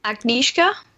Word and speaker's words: Agnieszka: Agnieszka.